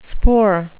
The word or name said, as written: spoor